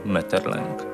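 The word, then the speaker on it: Maeterlinck: I wydaje mi się, że w tradycji przynajmniej polskiej, a może i w ogóle, przyjęło się wymawianie tego nazwiska jako Metterlęk.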